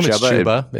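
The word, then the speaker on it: Chubba: I'm going to take, I don't even know how to pronounce it. I'm going to say it's Chuba Purdy. – I would assume Chuba, it's Chuba, that's how yeah, Chuba Hubbard pronounces it.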